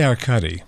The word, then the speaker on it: Arkady: Do you say Arkady Rinko is back? Is that how you pronounce it? – I say Arkady.